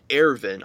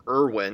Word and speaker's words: Erwin: The commander of the scout regiment, his name is pronounced Ervin. I just thought it was Erwin because that's like kind of how it's written, but I guess it's one of those, like, you know, sort of German names where it's a V, which does make it funny that in the preview the announcer person says Erwin.